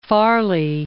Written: Farley

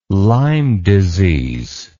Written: lyme disease

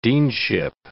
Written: deanship

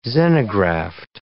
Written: xenograft

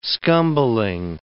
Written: scumbling

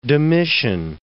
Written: Domitian

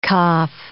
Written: kaph

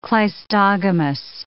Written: cleistogamous

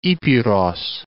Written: Epeiros

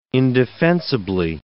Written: indefensibly

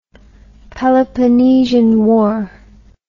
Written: Peloponnesian War

